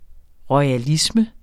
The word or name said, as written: royalisme